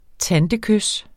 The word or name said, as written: tantekys